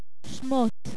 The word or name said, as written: Shemot